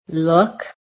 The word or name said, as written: LOOK